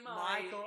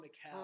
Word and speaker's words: Michel: Is it Michelle? Is it Mikel? Is it Mikel? – But it's Michael. It's M-I-C-H-E-L. – Yeah. – So I've been calling him Mikel.